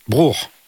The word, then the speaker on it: Bruch: Bruch. – Voilà, Max Bruch. Ah, merci pour le cours de prononciation.